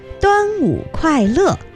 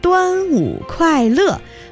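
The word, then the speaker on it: Duanwu Kuaile (端午快乐): Quite right, except that your pronunciation needs to be improved. It should be Duanwu Kuaile, Happy Dragon Boat Festival. Duanwu Kuaile, or Duanwu Ankang, wishing you health during the Duanwu Festival.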